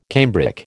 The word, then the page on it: cambric: American English